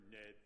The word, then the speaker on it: Ned: Anyway, as you heard, my name is Ned Bloom. I'm with the Red Cross. Should be easy to remember. Ned rhymes with red.